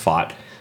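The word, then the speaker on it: Pfaadt: There is pitching prospect Brandon Fott, I believe is how you say his last name. It's P-F-A-A-D-T, but I believe it's pronounced Fott.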